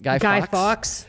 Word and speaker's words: Fawkes: I think the Guy Fawkes mask, how do you say that? – Guy Fawkes. – Yeah, I think that mask. – F-A-W-K-E-S.